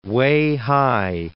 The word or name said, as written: Weihai